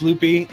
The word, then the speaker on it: Luppy: Yeah, you know, the biggest reason was most people don't know how to pronounce Loopy. It's spelled L-U-P-P-Y, and everybody would say Luppy.